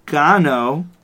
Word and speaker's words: Gano: Graham Gano also rhymes with guana.